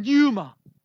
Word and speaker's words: pneuma: In Scripture, the words of Spirit are used for this Hebrew language, ruach, and in Greek, pneuma, in English pronunciation, pneuma, which gives words for pneumonia.